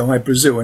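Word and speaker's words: Hy-Brasil: But it's High Brazil because that's how the Irish would pronounce it, because it's it's an island that's off the southwest coast of Ireland.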